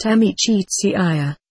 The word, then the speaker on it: Amicizia: In Italy, those most deeply involved named the group Amicizia, pronounced Amicizia, friendship.